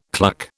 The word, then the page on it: cluck: British English